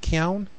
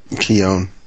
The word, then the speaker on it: Keown: Keown? – Keown.